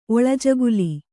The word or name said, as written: ಒಳಜಗುಲಿ